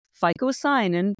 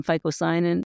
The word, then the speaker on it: phycocyanin: Fight that blue pigment in spirulina, which is called phycocyanin, P-H-Y-C-O-C-Y-A-N-I-N.